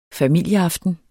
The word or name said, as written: familieaften